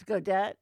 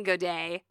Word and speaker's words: Godet: I'm saying Godet. I'm just gonna. I looked it up. It's like I looked up, you know, G-O-D-E-T pronunciation, and in English, that's like how it's pronounced.